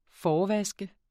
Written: forvask

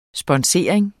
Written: sponsorering

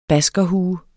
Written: baskerhue

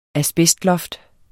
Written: asbestloft

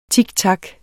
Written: tik

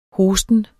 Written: hosten

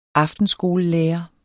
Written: aftenskolelærer

